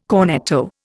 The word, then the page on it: cornetto: Indian